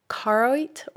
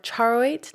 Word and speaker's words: charoite: The next stone is charoite or charoite, depends on where you're from, how you pronounce it, but it's spelled charoite.